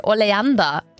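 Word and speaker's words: Oleander: Gonca yazmıştı ki oleanda diye okunuyor dedi.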